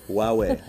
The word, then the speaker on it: Huawei: Huawei. I think we're going to put that one on. – How do you pronounce that? – Huawei. – Huawei.